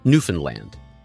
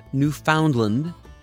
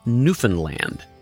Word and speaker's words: Newfoundland: And yes, that is how they say it Newfoundland. Not Newfoundland, and not Newfoundland. It's Newfoundland.